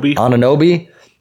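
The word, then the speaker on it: Anunoby: how do you say his last name? Ananobi. And then the way DiVincenzo's been stepping up since Julius Randle and Ananobi have been out.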